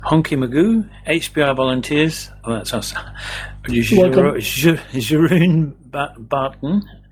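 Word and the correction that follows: Jeroen Baten: Jeroen Button.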